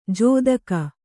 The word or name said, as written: ಜೊತ್ತಗ